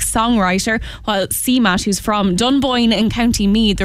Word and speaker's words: Dunboyne: So she's very delighted that a big American singer is saying Dunboyne in County Meath as she sings.